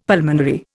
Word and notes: pulmonary: Indian

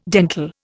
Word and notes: dental: Indian

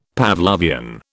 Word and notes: Pavlovian: British English

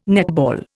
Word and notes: netball: Indian

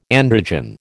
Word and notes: androgen: American English

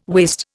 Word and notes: waist: Indian

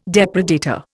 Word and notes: depredator: Indian

